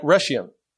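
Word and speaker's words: Rhegium: And from there, we made a circuit and a ride at Rhesium.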